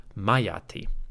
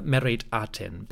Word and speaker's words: Meritaten: Adapting that to the name Meritaten, we may have a pronunciation closer to Meriati, or simply Mayati.